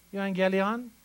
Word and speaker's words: euangelion: The gospel is the word in the Greek, euangelion, which, by the way, is not spelled with an N, but that's the way it is pronounced, euangelion.